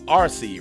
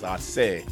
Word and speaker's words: Arce: So most people, as us Americans, always go, yeah, Gustavo Arce, right? Nah, it's Arce. So you say it properly.